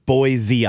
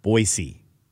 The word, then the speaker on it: Boise: And there's not Boise, Idaho. It's Boise, Idaho. – Okay, do that one. Run that by me again. That's close. – It's Boise, not Boise.